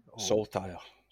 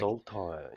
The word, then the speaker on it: Saltire: So if anyone actually wants to find you, I'll put because your race team is, how do you pronounce that? Is it Saltire? – Saltire. So the Scottish Saltire. It's a flag. – Saltire.